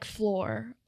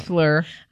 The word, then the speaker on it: Fleur: I'm like floor, like as if I'm like saying that so Southern. – As in like sweep the floor. It's like fleur.